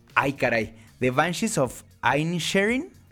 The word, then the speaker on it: Inisherin: Yo digo Inisherin porque supuestamente es lo más cercano al gaélico, pero Rafa Sarmiento dice Inisherin, que también puede ser más cercano al gaélico.